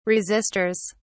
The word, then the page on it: resistors: British English